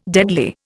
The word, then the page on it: deadly: Indian